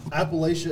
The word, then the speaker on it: Appalachia: Say yeah, Appalachia is how you say the word. That's how you say it.